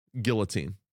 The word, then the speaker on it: guillotine: First of all, what if I told you that guillotine has a silent L? I'm just kidding. Moving on from that. – What did I say? – Guillotine.